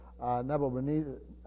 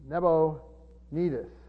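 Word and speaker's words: Nabonidus: Actually, he co-reigned with his father, Nebonidus, I keep pronouncing that wrong. Nebonidus, I guess, or Nidus, I think it's pronounced.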